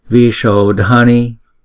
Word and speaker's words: Vishodhani: Om Bhara Bhara Sambhara Sambhara Indriya Vishodhani Vishodhani. Chữ S có dấu sắc thì chúng ta phải ểnh cong lưỡi lại và đọc S Vishodhani.